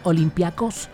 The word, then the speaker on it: Olympiacos: Ya se habría confirmado por completo el traspaso al Olympiacos o también Olympiacos, como lo quiera pronunciar.